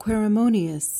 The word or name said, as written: querimonious